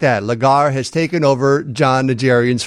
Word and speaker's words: Legere: I mean, I like the CEO of T-Mobile in particular, John Legere. Or Ledger, I guess he pronounces it, even though it looks like it should be Legere.